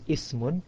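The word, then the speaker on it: ismun (اسم): So if there's no word before ismun, then you pronounce the Hamzatul Wasl, ismun.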